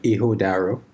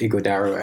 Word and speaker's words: Ighodaro: Natively, the G is silent, so it's more like Ighodaro. – Oh. – But a lot of people call it Ighodaro, and I kind of feel more comfortable, you know, with Ighodaro because it sounds better in my opinion.